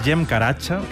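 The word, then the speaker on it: Cem Karaca: Este es Jem Caracha, que se escribe Zem Caracas, si lo queréis buscar.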